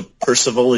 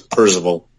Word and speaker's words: Parzival: They pronounce it essentially as close to Percival as you can, just with the Z. So it's Percival.